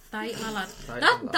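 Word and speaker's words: tahi lalat: When it comes to T, don't say T. You got to do it with the... – Yeah. – Tai. – Yeah. – Tai perfect. Lala. – Yeah. Tai lalar.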